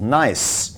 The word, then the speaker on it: Naiise: Now, in Singapore, this has once again become a particularly hot topic with the closure of one such chain called Nice. By the way, that is spelled N-A-I-I-S-E, in case you're looking it up on the internet.